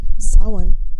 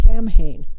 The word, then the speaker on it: Samhain: Samhain is a Gaelic word, and it is spelled S-A-M-H-A-I-N. Samhain looks as though it should be pronounced Samhain. That is an incorrect pronunciation, and it is pronounced Samhain, and deals with the dead again.